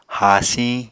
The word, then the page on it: haasiñ: Apatani